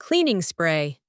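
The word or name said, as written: cleaning spray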